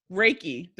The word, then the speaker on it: Reiki: So you also are, and I always fuck this up when I say it, Reiki. Did I say it right? – That's right.